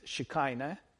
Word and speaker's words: Shekinah: But it's pronounced, it's corrupted into English as Shekinah, right? For the lack of the ch sound.